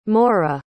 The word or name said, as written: morra